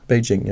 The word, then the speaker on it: Beijing: ist Beijing. mehr und mehr Beijing damals, heute spricht man immer noch so aus? Beijing, ja.